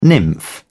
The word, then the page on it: nymph: British English